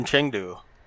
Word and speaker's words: Chengdu: They are Chinese, legit Chinese from Chengdu, which I hope I'm saying right. – Yeah, you are. I assume Good. You're saying it right because I heard someone else say it and they pronounce it like that.